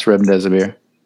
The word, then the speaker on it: Remdesivir: Is it Remdesivir? – Yes, Remdesivir.